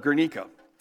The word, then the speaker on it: Guernica: It's pronounced, I looked it up, Guernica.